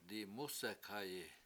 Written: dimUsek7hayi

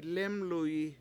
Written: lEmluyi

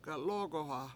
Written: galOOgoha

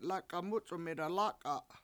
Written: lAk$a7 MUc$1m 7ida lAk$a7

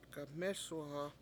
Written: gamEsuha

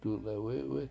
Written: dulewItwit

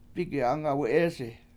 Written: Migi7Angawi7EEsi

